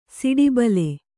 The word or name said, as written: ಸಿಡಿಬಲೆ